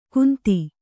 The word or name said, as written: ಕುಂತಿ